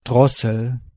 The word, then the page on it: throat: [θrəʊt]; /ˈθɹəʊt/; /ˈθɹoʊt/